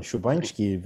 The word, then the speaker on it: Szubański: Magda Szubański, I believe it's correctly pronounced. – Correctly pronounced. Well done, Szubański.